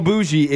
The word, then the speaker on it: bougie: You so bougie is B-O-U-G-I-E. – Yeah. – Which makes it seem like it's be pronounced boogie.